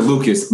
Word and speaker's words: Lucas: But the concept was Joyner Lacy. Did I say that right, Kasan? No, Joyner Lucas. My bad, Joyner Lucas.